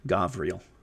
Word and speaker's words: Gabriel: Uh, Gabriel, sometimes Gavriel or Gabriel.